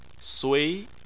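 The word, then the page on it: sui: tone 2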